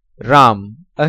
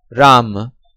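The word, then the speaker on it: Ram (राम): Usual transliteration, V-E-D-A. Sanskrit pronunciation, Ved. Hindi pronunciation, Ved. Foreigner's pronunciation, Veda. Next row, Hindi-Sanskrit word, Ram, a hero. Usual transliteration, R-A-M-A or R-A Macron, M-A. Sanskrit pronunciation, Ram. Hindi pronunciation, Ram. Foreigner's pronunciation, Rama. Next row, Hindi or Sanskrit word, Kam Sutra, a love manual. Usual transliteration, K-A-M-A-S-U-T-R-A.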